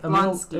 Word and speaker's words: Blonsky: You've been saying the other name wrong. – I thought I've said hit... Emil Jablonski is, like, his name in the show, I want to say. Mm-hmm. Jablonski? Blonsky? – Blonsky. – Emil Blonsky.